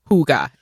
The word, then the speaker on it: Hooga: And now literally everything, like the invoices, the t-shirts, everything like pronounced Hooga. H-O-O-G-A. – What if we're still pronouncing it wrong, though? – How else can you say H-O-O-G-A? – Hey.